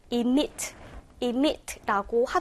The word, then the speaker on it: emit: 자, 생긴 것은 emit처럼 보이지만 원어민들 발음은요, emit, emit라고 하더라고요. 앞에 발음이 e로 된다라는 거 체크하시고 기본적으로 무슨 뜻이다?